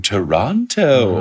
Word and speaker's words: Toronto: Ooh, Toronto. Mm-hmm. Or as I've been told to pronounce, Toronto. Yeah. I learned the hard way because I've always There's no second T.